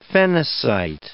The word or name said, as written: phenacite